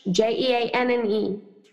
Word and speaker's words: Jeanne: First name is French, Jeanne.